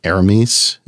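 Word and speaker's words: Hermès: They also came out with a bunch of new bands, and they have they're very happy about the I think it's like Aramis is how you say it. I think it's French, and I think the H is silent.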